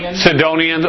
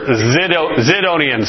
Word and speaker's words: Sidonians: Sidonians or Zidonians. Some translations have it with a Z.